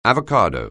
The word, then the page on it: avocado: anglais